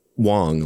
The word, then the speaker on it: Wang: Just Wang, by the way. – They said Wong. Like, really? – That's how they pronounced it in court, but I'm not totally. – W-A-N-G.